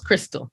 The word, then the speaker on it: Christal: So for me personally, you can go to my page on Instagram, Crystal Like Christ, C-H-R-I-S-T-A-L. Thanks, Mom. Mom and Dad. So Crystal N. Reynolds, Crystal.